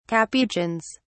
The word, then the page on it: capuchins: British English